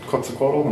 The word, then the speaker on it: Quetzalcoatl: Looks a lot like Quetzalcoatl. I'm not sure I'm pronouncing that right. – I think that's right.